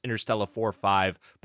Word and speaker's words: Interstella 5555: So Inter and then Stella, like the name, not Interstellar. Drop the R. It's cleaner. And then four number fives, 5555. It's pronounced Interstella 4-5, but stylized Interstella 5555.